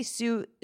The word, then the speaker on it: Sous: Did I say Sue therapist? Is that how it's pronounced, for like a sous chef? – Exactly.